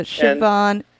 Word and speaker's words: Siobhan: So Siobhan, spelled like SIOBHAN.